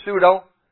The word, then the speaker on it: pseudo: But it has that silent P, that letter pseudo.